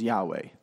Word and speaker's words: Yahweh: Scholars have recently discovered, and I think it's accurate, that the proper pronunciation is Yahweh.